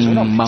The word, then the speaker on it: Sheinbaum: Explicó en un video cómo se pronuncia correctamente Sheinbaum.